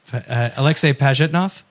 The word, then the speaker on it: Pajitnov: Alexei Pajitnov? – Boom.